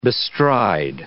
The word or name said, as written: bestride